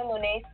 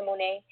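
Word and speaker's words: Moná: Like, my full name is Sasha Monet Small. – Okay. – And so my middle name is spelled M-O-N-A with like a little like an accent on the A, you know, for the Monet.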